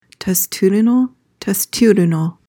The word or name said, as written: testudinal